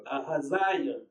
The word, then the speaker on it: Ahaziah: Ahazal. What? How do you pronounce that? – Ahaziah.